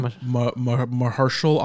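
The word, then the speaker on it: Mahershala: Mahershal Ali. – Mahershal Ali.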